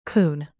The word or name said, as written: coon